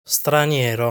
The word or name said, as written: Straniero